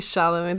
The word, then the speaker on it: Chalamet: Oh, we got Timothy Chalamet. Did I pronounce it wrong? – Timothy Chalamet.